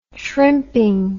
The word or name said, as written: shrimping